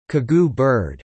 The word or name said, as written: Cagou bird